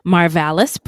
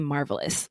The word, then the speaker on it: Marvallis: Marvallis, pronounced Marvallis, and you, uh, I heard on a podcast where they were like, I'm just going to call him Marvelous. Well, that's not his name.